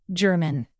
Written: german

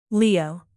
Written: leo

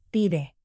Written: pide

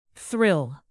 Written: thrill

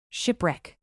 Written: shipwreck

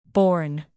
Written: borne

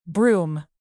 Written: broom

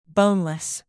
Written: boneless

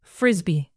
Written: frisbee

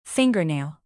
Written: fingernail

